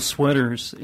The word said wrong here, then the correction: Schwitters: is That's how you pronounce it, Schwitters? – Schwitters, yes.